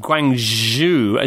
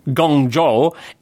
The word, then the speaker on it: Guangzhou: Ah, the problem about the pronunciation of Guangzhou is that it's actually happening in two different dialects of Chinese. If you were to say it at Guangzhou, as it appeared, that would be the Mandarin way of saying it, you see?